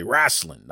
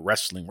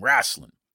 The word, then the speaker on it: Rasslin: But it's funny for me to correct James and say Rasslin, not wrestling, Rasslin. And James trying to, you know, his media training, so he's trying to properly pronounce the word. It's wrestling. It was like, no, the name of the show is Duke Loves Rasslin.